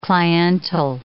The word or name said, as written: cliental